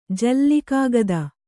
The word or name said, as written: ಜಲ್ಲಿಕಾಗದ